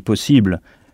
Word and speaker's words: possible: Et attention, on n'insiste pas sur le « i » comme en français. En français, on dit « possible ». Mais sur la syllabe qui précède cette terminaison. Ça donne « able »,« possible »,« possible ». C'est comme si on frappait fort sur cette syllabe.